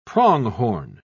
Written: pronghorn

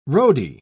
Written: roadie

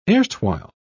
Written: erstwhile

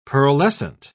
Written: pearlescent

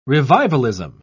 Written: revivalism

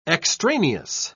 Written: extraneous

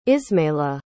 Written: ismaela

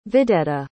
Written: vedetta

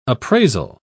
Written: appraisal

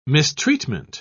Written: mistreatment

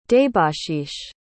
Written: debashish

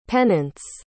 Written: pennants

